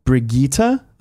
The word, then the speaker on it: Brigitte: The new Overwatch hero's name is pronounced closer to Brigitte, apparently.